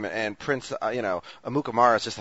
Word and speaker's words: Amukamara: And I commend you for pronouncing Prince Amukamara's name uh, correctly.